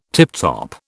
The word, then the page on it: tiptop: British English